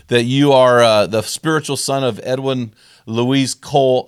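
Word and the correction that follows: Louis: No, how do I pronounce his middle name? – Lewis. – Edwin Lewis Cole, who I've read his books, and he's a phenomenal human, and I'm friends with his son, Paul, now.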